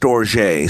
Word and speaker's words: storge: And then there's a compound word called, I think it's pronounced storge. Storge, it's storge with an E on the end of it.